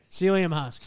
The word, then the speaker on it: psyllium husk: Loved a story Mark told on the old pod about buying psyllium husk. Is that how you pronounce it? – Yeah, psyllium husk.